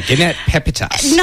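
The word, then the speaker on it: Petitpas: Yeah, Jeanette Pepitas. – Not Jeanette Pepitas, it's Jeanette Pepita.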